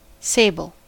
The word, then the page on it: sable: American English